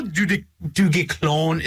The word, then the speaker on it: du Guesclin: Do you want to know how du Guesclin is spelt, man? D-U-G-U-E-S-C-L-I-N.